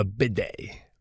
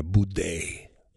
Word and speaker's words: bidet: A bidet. – Bidet, not a boudet.